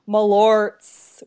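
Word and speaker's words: Malört: Malorts with a Z at the end.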